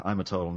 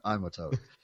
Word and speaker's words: Imatot: And he spells his and he pronounces his first name Imatot, not I'm a toad.